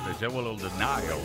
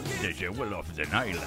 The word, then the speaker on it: The Jewel of the Nile: The Jewel of the Nile. ¿Lo dije bien? No, ¿verdad? The Jewel of the Nile, ahora sí.